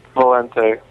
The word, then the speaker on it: Balente: Valente. – How do you spell that? – B-A-L-E-N-T-E.